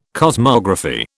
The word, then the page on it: cosmography: British English